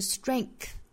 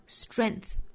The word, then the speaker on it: strength: 那这个词呢有两种读法，我们可以不把中间的这个 k 的发音，也就是字母 G 代表的这个 k 的音读出来，也可以不读出来。那两种读法是 strength 和 strength， strength 和 strength。那我们来看下面的这个 preference poll，大多数人还是会把这个 k 的发音读出来的， strength 注意 k 的音发完之后去发 th，昨天我们讲到 th 啊，舌尖伸出口腔外头去摩擦上排牙齿，然后呢在舌齿之间会有气流通过，送气发出 strength。